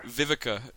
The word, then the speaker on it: Vivica: And how do you say her name? Vivica A. Fox or... – Vivica, it's Vivica. – Vivica, let me set that one over.